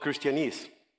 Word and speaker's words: Christianese: It's called Christianese. It rhymes like Chinese, but it's Christianese.